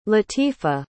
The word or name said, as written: latifah